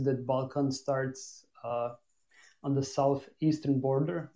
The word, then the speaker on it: Balkan: Am I saying it right? The Balkan Peninsula, right? – Yes.